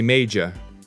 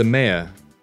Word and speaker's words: mayor: Students often say major, but it's the mayor. The mayor. It's not pronounced major, it's mayor.